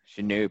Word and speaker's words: Shinobi: You got to call it Shinobi. – Shinobi. – Because it rhymes with Toby.